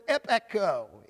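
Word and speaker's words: epecho (ἐπέχω): It comes from the Greek word epecho. It's spelled E-P-E-C-H-O. Epecho.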